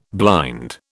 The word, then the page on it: blind: British English